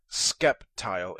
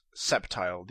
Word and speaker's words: Sceptile: But first of all, Sceptile is not Skeptile, it's Sceptile. The C is silent.